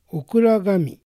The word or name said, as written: Okuragami